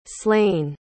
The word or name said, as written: slaine